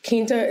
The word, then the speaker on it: Quinta: I always thought that Quinta was Quinta. So are we all saying it wrong, what's going on? – No, Quinta is another pronunciation of my name. Some would say that is the correct pronunciation. I've kind of grown up my whole life being called both Quinta and Quinta. – Okay. – I am the fifth child. Quinta means fifth.